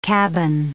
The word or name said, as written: cabin